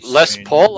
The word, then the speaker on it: Les Paul: It has to be Les Paul, like the guitar.